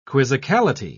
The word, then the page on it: quizzicality: British English